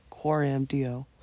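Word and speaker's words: Coram Deo: Yeah, the it's Latin. So the official Latin pronunciation is CoramDeo.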